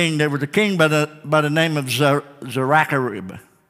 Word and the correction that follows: Sennacherib: Sennacherib, Sennacherib.